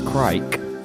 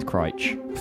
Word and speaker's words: Crich: Like, I went to Crich the other day, and I always pronounced it as Crike. – I thought it was Crick, but you know. – Well, who knew? Anyway, the locals tell me it's Crich.